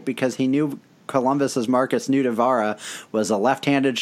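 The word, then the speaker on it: Nutivaara: Jake Allen made an incredible save on Marcus Nutella. No, that's not how that's pronounced. Nutavara.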